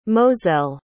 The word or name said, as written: mozell